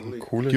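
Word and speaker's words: Kulick: Kulig.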